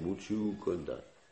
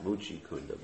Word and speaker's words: Muchukunda: And he ran into a cave, and in that cave was this nice devotee of Krishna named Muchukunda. Not Muchikunda. Most devotees mispronounce that name. Muchikunda means a dirty lake. You know, Muchikunda. Like Radhakunda, they have Muchikunda. So Muchukunda is his name.